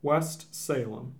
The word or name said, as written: West Salem